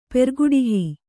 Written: ಪೆರ್ಗುಡಿಹಿ

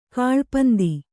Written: ಗೌಳಿಪಂತು

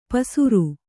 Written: ಪಸುರು

